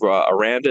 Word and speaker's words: Aranda: I think the first one to blink of two, whoever signs, whether it be Franklin or Dave Aranda, is that how you say his name properly? – Yes.